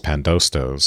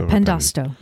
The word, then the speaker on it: Pandosto: But he's taking these fantastic elements from Robert Greene's Pandosto. So although he reverses, is that how you pronounce it? – Yeah, Pandosto.